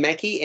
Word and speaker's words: Mackey: A role filled – do you pronounce her name Mackey, M-A-C-K-E-Y, Emma Mackey? – Yes, that's what Rob said, yeah.